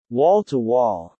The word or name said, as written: wall-to-wall